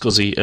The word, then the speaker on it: SCSI: SCSI stands for, it's pronounced SCSI, S-C-U-Z-Z-Y. Or ZZY, and it stands for Small Computer System Interface, and it's a general purpose interface used for connecting devices to PCs.